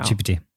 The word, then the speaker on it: GPT: It is G? – I'm very confident, yes. – Which one is it? – GPT. – What did I say? How do I say it wrong? Oh, I was saying GBT in the past, wasn't I? – Yeah. – Chat GBT. Yeah.